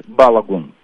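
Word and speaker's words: Балогун: Пишут, какое правильное ударение. Как правильно? Белагун, Белагун. Белагун? – Он говорит Белагун, да. Он говорит вот так.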